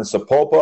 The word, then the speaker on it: Sapulpa: Parker uh, from down in Sapulpa, Oklahoma.